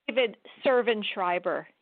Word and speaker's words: Servan-Schreiber: Um, oh, sorry, David, oh, doctor, it's David Servan-Schreiber.